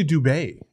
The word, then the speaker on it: Dubai: It's actually Dubai. Did you know that? – Well, we call it Dubai over there. I don't care what you call it. – the correct pronunciation.